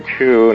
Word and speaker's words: Cheu: We've been talking with Richard Cheu, and I want to spell that for our listeners. It's C-H-E-U, as in chew your food, young man.